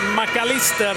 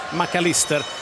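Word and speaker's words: Mac Allister: till exempel McAllister. De säger McAllister i Argentina, de säger inte McAllister, även om man har ett engelskklingande namn.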